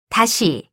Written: 다시

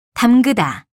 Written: 담그다

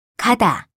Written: 가다

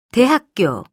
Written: 대학교

